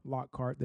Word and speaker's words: Lockhart: So for all of you who don't know, my name is Myah Lockhart. That's spelled M-Y-Y-A-H Lockhart, L-O-C-K-H-A-R-T.